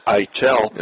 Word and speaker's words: Eitel: Chuck, I never knew the accent was on the second syllable. – Me either. – I've called him Edel. Eitel, it's Eitel. Yeah.